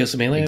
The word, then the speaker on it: Osemele: Uh, Kelechi Osemele.